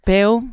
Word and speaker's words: Beale: Maar met hem eigenlijk nog meer Mick Beale, volgens mij spreek je het zo uit, en Steven Gerrard.